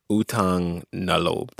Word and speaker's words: utang na loob: Utang na loob. Did I, did I say that right? – Yes.